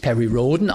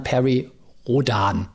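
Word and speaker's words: Perry Rhodan: ja, der uns darauf hingewiesen hat, dass Perry Roden eben nicht Perry Roden ausgesprochen wird, wie ich das getan habe, unserer Russland-Folge, sondern Perry Rodan.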